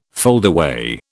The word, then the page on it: foldaway: British English